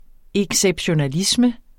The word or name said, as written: exceptionalisme